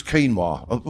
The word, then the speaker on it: quinoa: Quinoa? No, it's quinoa. Why don't you spell it like that?